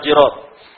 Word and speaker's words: جراب: كذلك يقول على الجراب لا تفتح الجراب، والجراب بكسر الجيم ولا ليس بفتحها، لا تفتح الجراب ولا تكسر القصة، وهكذا.